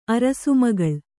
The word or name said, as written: ಅರಸುಮಗಳ್